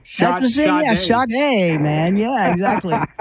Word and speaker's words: Sade: I called him Sade. I'm like, oh, I never lived that one down. So yeah, Sade.